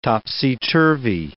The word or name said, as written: topsy-turvy